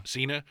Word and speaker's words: Cena: you know, and unfortunately that's a big part of everyone's jobs usually, but you know, John Cena has a thing that, is that how you say his name? – Cena.